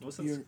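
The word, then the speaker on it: Björk: York? – No, actually, maybe something off Medulla. Yeah, yeah. – It's pronounced Bjork. – It's kind of horrifying. – Like jerk.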